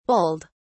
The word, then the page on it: bulled: British English